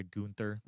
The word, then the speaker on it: Gunther: The Intercontinental title match, I didn't care about because I like Gunther, not a huge fan, or I'm sorry, Gunther, that's how they pronounce it.